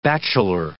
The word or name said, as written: bachelor